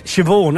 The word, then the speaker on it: Siobhan: It's like shebang is Siobhan, isn't it?